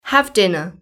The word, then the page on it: have dinner: British English